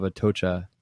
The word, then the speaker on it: Atocha: waiting for the holy infant of Atocha.